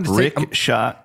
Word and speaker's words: Richat: Rickshaw structure. – RICHAT, I believe. The rickshaw structure is how it's pronounced.